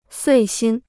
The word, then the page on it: 遂心: suì xīn